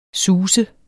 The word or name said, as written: SUSTES